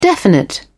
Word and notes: definite: British English

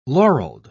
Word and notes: laureled: British English

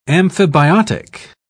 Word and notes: amphibiotic: British English

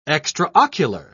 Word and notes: extraocular: British English

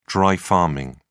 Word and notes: dry farming: British English

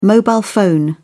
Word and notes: mobile phone: British English

